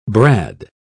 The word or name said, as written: brad